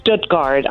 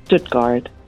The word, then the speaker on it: Stuttgart: Whatever you want to say. It's Stuttgart. I mean, I'm from the area. That's how we pronounce it. Stuttgart, but most people say Stuttgart.